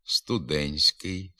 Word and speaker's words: студентський: Пишемо «студентський», «студентство», «туристський», а вимовляти треба «студентський», «студентство», «туристський».